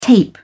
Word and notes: tape: British English

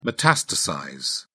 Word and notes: metastasize: British English

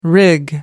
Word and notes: rig: American English

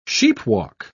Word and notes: sheepwalk: British English

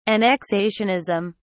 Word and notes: annexationism: British English

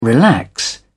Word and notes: relax: British English

